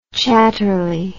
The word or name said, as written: chatterley